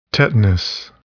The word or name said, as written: tetanus